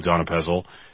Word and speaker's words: donepezil: confidently asserting that his third variant of Donepezil, everyone knows that first E is silent, is in fact the authoritative version.